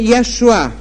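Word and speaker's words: Яшуа (ישוע): Еврейското произношение Яшуа.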